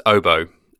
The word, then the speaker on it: oboe: So the correct pronunciation is oboe, as in after the musical instrument oboe.